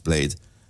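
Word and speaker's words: Bladee: He spells it, it looks like Blade-y, but he pronounces it Blade.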